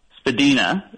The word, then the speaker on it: Spadina: Spadina is how you say that one.